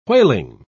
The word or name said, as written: whaling